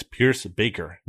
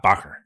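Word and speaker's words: Pierschbacher: It's Pierce Baker, not Bacher. Okay, it's very tempting to say Bacher because it's spelled B.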